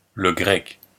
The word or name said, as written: le grec